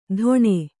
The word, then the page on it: ಧೊಣೆ: dhoṇe